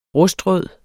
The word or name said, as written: rustrød